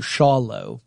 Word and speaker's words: Schawlow: Shallow. Or Shallow, I guess is the way you would pronounce it. S-C-H-A-W-L-O-W, Shallow.